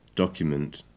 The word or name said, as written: document